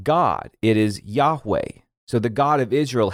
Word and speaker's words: Yahweh: But when you see "Lord," it's probably small caps, and the English letter equivalent would be YHWH, and the best pronunciation we can get in English is Yahweh. Now, there are some interesting things going on there with the vowels, which we won't get into in this podcast, but this name was written so as to make it actually hard to pronounce. Your King James Bible, if you have that, will sometimes say Jehovah, which is kind of close, not quite there.